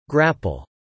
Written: grapple

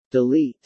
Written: delete

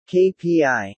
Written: KPI

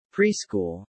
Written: preschool

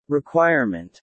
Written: requirement

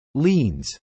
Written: leans

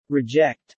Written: reject

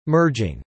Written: merging

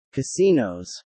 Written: casinos

